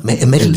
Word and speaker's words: Mädel: Also das, das T, Metal, das T vom Metal einfach so ein bisschen weicher ausgesprochen. ausgesprochen. Nochmal. Medel. Das kommt, jetzt lassen wir, verarsch mich, verarsch mich Medel. Medel. Medel. Medel, wie Medaillon, weißt du?